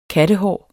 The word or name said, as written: kattehår